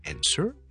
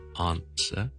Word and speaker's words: answer: 美式是 answer, 英式是 answer。